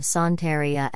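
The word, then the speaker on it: Santería: Santeria, Spanish pronunciation, Santeria, also known as Regla de Ocha,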